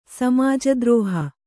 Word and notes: ಸಮಾಜದ್ರೋಹಿ: samāja drōhi

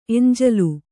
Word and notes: ಎಂಜಲು: eñjalu

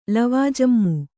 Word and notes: ಲವಾಜಮ್ಮು: lavājammu

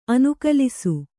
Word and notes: ಅನುಕಲಿಸು: Kannada

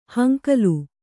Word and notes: ಹಂಕಲು: hankalu

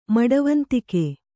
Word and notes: ಮಡವಂತಿಕೆ: maḍivantike

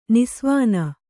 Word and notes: ನಿಸ್ವಾನ: nisvāna

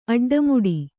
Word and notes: ಅಡ್ಡಮುಡಿ: aḍḍamuḍi

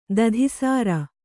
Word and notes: ದಧಿಸಾರ: dadhi sāra

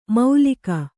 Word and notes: ಮೌಲಿಕ: maulika

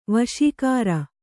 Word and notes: ವಾಸಾಗಾರ: kannada

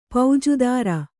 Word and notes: ಪೌಜುದಾರಿ: paujudāri; Kannada

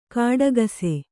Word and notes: ಘಟಾಕಾಶ: Kannada

